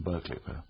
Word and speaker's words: Berkeley: cerca del el castillo de Berkeley, que los americanos pronunciarían Berkeley, pero hay poco mención de esas cosas.